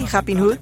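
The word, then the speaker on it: Rappin' Hood: Am I pronouncing that correctly? Rapin Hood? – Yes.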